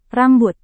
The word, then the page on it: rambut: indonesian